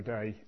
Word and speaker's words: Dei: I think that's how you pronounce that last word. I've been pronouncing it wrong up until about half an hour ago, and then Dave's put me right. But I think it's day. I'm just going to say Missio Dei, and we'll go from there.